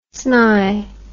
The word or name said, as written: sny